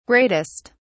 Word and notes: greatest: British English

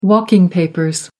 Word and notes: walking papers: American English

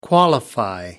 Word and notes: qualify: American English

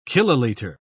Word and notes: kilolitre: British English

